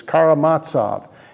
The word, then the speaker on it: Karamazov: In his Knowledge, the brothers Karamazov, or Karamazov. There are different ways to pronounce that, and I was corrected one time and said it's Karamazov, and then I've been corrected.